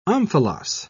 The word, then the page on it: omphalos: British English